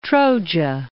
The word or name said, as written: Troja